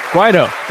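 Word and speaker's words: Guaidó: Guaido, that's how you say it. Guaido. We were close.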